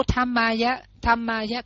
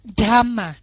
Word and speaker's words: dhamma: Như cái giống chữ dhamma là pháp á, quý vị đọc thành tham nam mô tham ma, quý vị đọc chữ thơ luôn nó không có đúng. Quý vị phải đọc là dhamma dhamma d d h, đọc nhanh nối lại với nhau nó thành ra một âm, nhưng mà nó không phải là âm t mà nó là âm d dhamma.